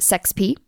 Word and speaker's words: sexp: Uh, generated by Ripper.sexp expression, which I think is much better uh, when it's pronounced sex p.